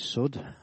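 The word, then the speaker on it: Sod: It's not pronounced as you think, it's Sud.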